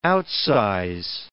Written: outsize

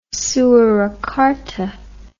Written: soerakarta

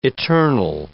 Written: eternal

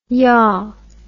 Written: yaah

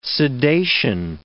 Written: sedation

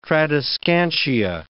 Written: tradescantia

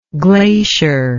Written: glaciered

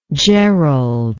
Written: gerald

